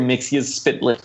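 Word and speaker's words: Mxyzptlk: Yeah, Mr. Mixius Spitlick. At least that's how they pronounced it in the Superman cartoon, which, funny enough, I did enjoy.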